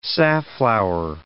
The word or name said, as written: safflower